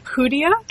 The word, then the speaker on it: Hoodia: Rosy periwinkle, Asiatic pennywort, grains of paradise, Strophanthus, Cryptolepis, forgive my pronunciation here and beyond, Hoodia? – Yes. – Is that right? – Yeah.